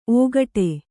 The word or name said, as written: ಔಕಾಟ